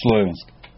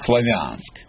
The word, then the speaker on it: Славянск: В Славянск. Ну, русские говорят Славянск. А это только Славянск ударение.